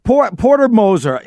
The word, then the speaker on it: Porter Moser: Hey, real quick, let me Porter Moser.